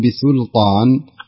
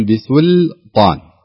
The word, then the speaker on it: بسلطان: في قوله تعالى إني آتيكم بسلطان يعني اللام أتى بعد حرف استعلاء اللي هو الطاء فلذلك ما تقول بسلطان لا تقول بسلطان تنطق اللام مرققة ثم تنطق إلى الطاء بحرف استعلاء أو حرف تفخيم.